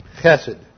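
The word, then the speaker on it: Chesed (חסד): Chesed. This is a hard C-H-E-S-E-D. It's kind of almost a soft T-H because it has no doggish there.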